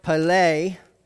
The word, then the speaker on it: Pelée: and it's pronounced Pelée, but I couldn't do the little emphasized things over there, but it's Pelée, Mount Pelée.